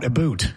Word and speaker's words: about: No, but if you're going to say about, a boot. Because the Canadians pronounce it a boot.